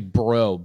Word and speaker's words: Brault: I know this isn't how you pronounce his last name, but it's spelled Brault. Dan. – Oh Bro. – Yeah. Dan Bro. It's actually Bro. B-R-O is how you pronounce it.